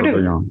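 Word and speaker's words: Erdoğan: Well, Victor, let's go ahead and turn to Turkey, who um, for the past few weeks or so we see, and I'm gonna I'm gonna pronounce his name all wrong, but Erdogan. Um, That's right. – Sort Erdogan. of threatening the Kurds did I do it well? Okay, great. Yes.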